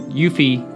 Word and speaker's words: Yuffie: And you leave out of the Dark Depths and you see that Squall, Aerith, and Yuffie is outside.